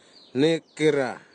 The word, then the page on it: nekira: Nafe; Tanna